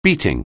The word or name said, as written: beating